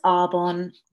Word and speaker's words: Arbonne: It's Arbonne. It's an interesting word. It actually means beautiful tree. But so many people call it Arbonne, Arbonne.